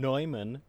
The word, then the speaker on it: Neumann: It is not Adam Neumann, it is Adam Neumann.